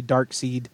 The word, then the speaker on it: Darkseid: Axis is spelled UXAS, just like Darkseid, which looks like it should be Darkseid and screws up every comic noob until somebody explains to him, no, that's Darkseid.